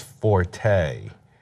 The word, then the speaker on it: forte: The correct pronunciation is fort. – Okay, yeah. Well, I didn't know that. – Okay. Yeah. Because it comes from the French, maybe it comes from the Italian. It's either from the Italian or the French, but I guess it would be French.